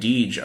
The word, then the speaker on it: Deej: And you have the, you know, Bungie and Deej are, I think that's how you pronounce his name is Deej, D-E-E-J.